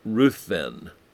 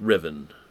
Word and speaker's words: Ruthven: You'd think it'd be pronounced Ruthven, but it isn't. It's pronounced Riven, Riven Todd.